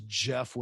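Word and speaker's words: Geoff: My name is Jeff with a G.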